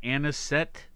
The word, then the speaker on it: Anisette: National Anisette Day. Did I say it right? Uh, that's how I would do it, yes.